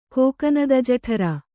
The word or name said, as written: ಕೋಕನದಜಠರ